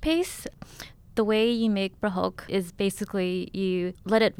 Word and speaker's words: prahok: Am I saying that right? – Oh yeah, prahok. Perfect.